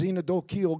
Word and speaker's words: xenodochial: That sounds like it starts with a Z instead of an X. Yes, it does sound like it starts with a Z. But it starts with an X. And it's spelled X-E-N-O-D-O-C-H-I-A-L, which means friendly to strangers.